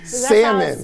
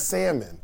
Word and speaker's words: salmon: Because salmon. You know what I mean? I take the L off and everything. I'm fancy. It's salmon.